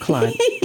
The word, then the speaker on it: Chluaid: But it's Chluaid, and it's pronounced Chluaid. No, it's pronounced Clyde. Pretty much everybody who sees it without knowing how it's pronounced, unless they're Scottish and they know a little bit of Gaelic. They'll just say Chluaid, but it's Clyde.